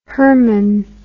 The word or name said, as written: Hermann